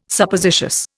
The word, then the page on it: Suppositious: Indian